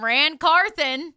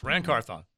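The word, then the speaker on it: Ran Carthon: Titans general manager, going to say it right this time, Rand Carthon. – Didn't say it right this time. – Well, it's still good. You're close. Rand Carthon.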